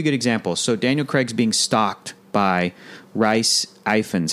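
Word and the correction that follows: Rhys Ifans: Reese Ifans? – No, I think it's Reese. – Got it. – Reese. He's being stalked by Reese because Reese Ifans.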